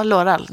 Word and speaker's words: Laurel: Är det Laurel, eller? – Ja, Loral. – Det, Loral. Ja, ja, Loral. det är Loral mm.